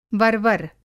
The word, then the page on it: वर्वर: Hindi